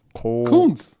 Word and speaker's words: Koonce: K-O-O-N-C-E, wie spricht man das aus? – Kunz. – Kunz, okay. – Kunz. – So, Malcolm Kunz. – Doppel-O ist doch U in Englisch.